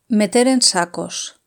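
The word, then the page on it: meter en sacos: European Spanish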